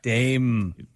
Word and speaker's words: Daim: Plus, they changed the name from dime to dime. D-A-I-M. – Yeah, but you still pronounce it like a dime bar. – Dame.